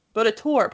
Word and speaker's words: Baudetort: Baudetort, probably, because it's French. You don't pronounce that T.